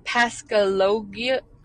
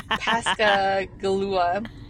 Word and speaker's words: Pascagoula: Pascagoula. Oh my God, I'm sorry, guys. Pascagoula.